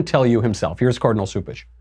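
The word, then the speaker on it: Cupich: But Cupish, I think it's actually pronounced Soupish, but people call him Cupcake, so then they think it's Cupish. But I believe it's Soupish, whatever his name is.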